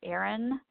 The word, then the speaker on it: Erin: And my name is spelled Erin, E-R-I-N, Chamerlik, C-H-A-M-E-R. L-I-K, and mention this podcast because I don't let just anybody in.